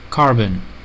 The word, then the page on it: carbon: American English